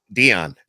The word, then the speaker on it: Dion: Uh, by the way, it's Dion Dion Dion Alexander is. uh That's right. – Dion. That's it. He said, pronounce Dion. I apologize for the miss. I have no idea. I've never seen it spelled like like the way you spell it, but uh, so it's pronounced Dion. So that's cool.